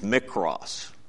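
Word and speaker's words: mikros (μικρός): That word is pronounced mikros, little ones. Mikros. We get the word micro from it.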